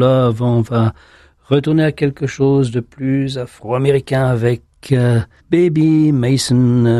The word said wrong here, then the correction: Bobby Mason: Enfin, je prononce toujours mal son nom, c'est Bobby. Bobby Mason avec Holy Spirit, You Are Welcome Here.